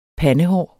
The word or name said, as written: PANDES